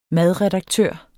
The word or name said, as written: madredaktør